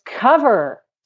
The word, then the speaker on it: covert: It's cover. COVERT. Cover. The T is silent.